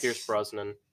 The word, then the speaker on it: Pierce Brosnan: Because I do like Pierce Brosnan.